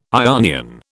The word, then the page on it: Ionian: British English